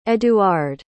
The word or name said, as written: eduard